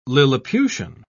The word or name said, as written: lilliputian